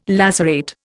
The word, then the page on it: lacerate: Indian